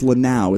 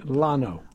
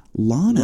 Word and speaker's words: Lano: As we gently, it's Lanao, is that correct? – No. – How do you say it? – Lano. – Lano. – Lano. – Wow. – It was spelled L-A-N-O, Lano.